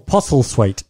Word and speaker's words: Postlethwaite: Sounds like, you can't say it without sounding like a speech impediment. Pete Postlethwaite. – Postlethwaite. – It's not Postle. It's Postle. – Postlethwaite.